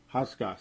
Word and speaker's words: Hauschka: Stephen Hosaka is going to make this. Stephen Hauschka, excuse me, Stephen Hauschka. I don't know why I'm mispronouncing it so bad. I probably still am butchering it.